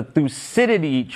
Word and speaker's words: Thucydides: Christiane, you're about to take us to school because there have been a ton of searches about the Thucydides trap that I guess President Xi brought up to President Trump.